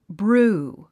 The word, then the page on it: brew: brúː; ブルー